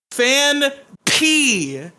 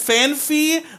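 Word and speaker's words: Phanpy: It is my good friend, my sweet blue elephant boy, Fan P. Anybody who says Fan Fee, literally kill them.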